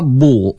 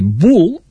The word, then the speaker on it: bull: Una obra de teatre que es diu, eh, a veure, Europa Bull, eh, o, o sí, es pot llegir Bull eh, com en anglès, eh, bull, brau, toro, eh? o Europa bull, o sigui, ho pots pronunciar la catalana, perquè Europa també bull.